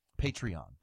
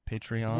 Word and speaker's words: Patreon: But if you were to become a patron. – Patreon. – Yeah, it's spelled Patreon, I think. – Patreon.